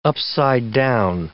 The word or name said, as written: upside down